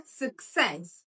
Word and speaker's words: success: So the stress is on the second syllable. It has two syllables. So you go success.